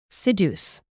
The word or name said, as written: seduce